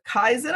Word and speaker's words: Kaizen: Mark is also the co-author of Joe Swartz of Healthcare Kaizen, and hopefully I said that properly.